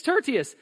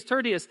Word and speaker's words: Tertius: It's Tertius. I practiced. That's the hardest one for the whole list for me is Tertius.